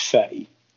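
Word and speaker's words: Fe: Well, I have it on good authority that it's pronounced Fey, but it's spelt F-E.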